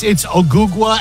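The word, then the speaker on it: Ogugua: No, it's Ogugwa Anunoby Jr. Ogugwa.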